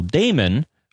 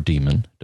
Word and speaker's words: daemon: And there's a little daemon, or demon, depending on how you want to mispronounce it, called NTPD, which you should see running.